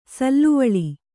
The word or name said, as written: ಸಾಲುವಳಿ